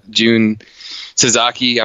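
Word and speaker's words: Jun Tazaki: First of all, Jun Sasaki, I'm sure I'm butchering his name, right? – No, you actually got that one right.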